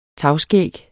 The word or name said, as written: tagskæg